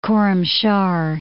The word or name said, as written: Khorramshahr